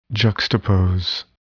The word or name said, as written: juxtapose